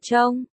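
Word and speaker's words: trông (钟): Đồng hồ trong tiếng Trung đọc là trông. Đồng âm với từ kết thúc cuộc đời cũng đọc là trông.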